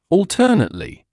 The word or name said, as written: alternately